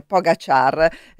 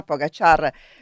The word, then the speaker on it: Pogačar: Ringrazio Stefano che mi dice buongiorno, che bello sentirti la mattina, ma giustamente corregge anche la pronuncia perché è Pogačar, quindi riprendo un attimo la notizia, pagina 35 del Corriere della Sera, Pogačar.